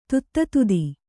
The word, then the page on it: ದಧಿತ್ಥ: dadhittha